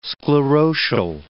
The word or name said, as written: sclerotial